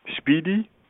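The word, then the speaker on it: SPDY: SPDI. Speedy, okay. Pronounced.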